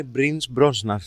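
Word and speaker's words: Pierce Brosnan: Σε αυτή τη γειτονιά ζει ο Μπρις Μπρόμπραν, ο οποίος λέγεται, πώς λέγεται, Λάρι Άντζελο, με αγγλική προφορά. – Νομίζω Άντζελο. Σωστή. – Λόρενς Άντζελο. Η σωστή προφορά είναι Μπρις Μπρόσναν.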